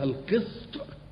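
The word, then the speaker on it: القِسط: احنا قلنا القسط ده يعني معناه العدل، وقلنا ان الماده دي اللي هي القاف والسين والطاء مره تنطقها القسط بكسر القاف ومره تنطقها القسط بفتح القاف. القسط بالكسر هو العدل، والقسط بالفتح هو الظلم.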